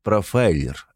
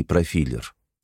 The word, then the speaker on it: профайлер: «И, кстати, правильно говорить «профайлер», а не «профилер».